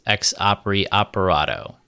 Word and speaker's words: ex opere operato: Yes, ex opere operato.